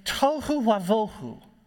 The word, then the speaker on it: tohu wavohu: It's tohu wavohu. – You got it. – Yeah, thank you, Porter. Thank you. It rhymes in Hebrew. Tohu wavohu. Tohu is a pretty common word in the Hebrew scriptures, and it just means it's a situation where there's just no purpose.